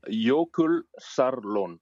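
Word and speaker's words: Jökulsárlón: Jokulsarlon.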